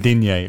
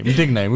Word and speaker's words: Digne: That's how you say his name, though, innit? – Digne. Digne. We'll give you Digne.